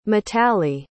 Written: mitali

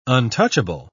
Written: untouchable

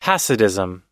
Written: hasidism

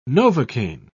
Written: novocain

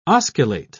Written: osculate

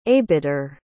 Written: abider

